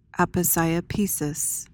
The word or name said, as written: aposiopesis